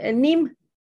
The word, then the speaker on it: neem: ¿Cuál es? NIM. ¿Cómo se escribe? N-E-E-M.